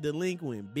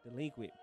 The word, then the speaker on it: Dlequint: I need you to say, it's pronounced delinquent, bitch. – It's pronounced delinquent, bitch.